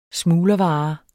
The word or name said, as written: smuglervare